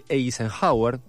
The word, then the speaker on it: Eisenhower: Porque lo tiene a Brendan Fraser, que hace de Eisenhower, creo que se pronuncia así. – Sí, muy bien.